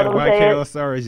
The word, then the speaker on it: YK Osiris: YRK Osiris. Am I saying it right? – Yeah, YK Osiris, yep.